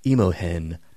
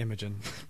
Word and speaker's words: Imogen: But someone is going to reach out to me like it's pronounced Imohen Pwats." Uh, probably not. – I no, probably just made that up. It is definitely Imogen.